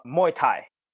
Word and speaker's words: Muay Thai: It's Muay Thai. Is that how you pronounce it? Uh, Muay Thai.